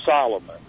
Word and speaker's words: Salomon: And I think the last name is spelled S-A-L-M-O-N. – Uh-huh. – More like Salomon than Solomon. But yeah. – Pronounced Solomon.